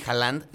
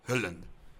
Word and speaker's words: Haaland: Siempre me cuesta mucho trabajo pronunciar su apellido porque no es ni Haaland ni Holland. Holland. Es como Holland. Holland.